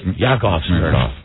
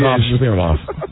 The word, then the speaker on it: Yakov Smirnoff: Yakov Smirnoff. – No, that's not the right pronunciation. – Yakov Smirnoff.